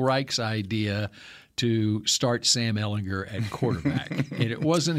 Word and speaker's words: Ehlinger: Ehlinger.